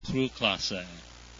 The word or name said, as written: cru